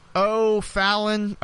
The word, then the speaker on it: O'Fallon: O'Fallon. O is kind of like the play on my middle initial, because I use it. And Fallon kind of rhymes with Mallon, so there you go.